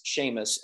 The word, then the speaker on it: Seamus: The chairman is Seamus, and sorry for our friends from, you know, the UK. – It rhymes with shame.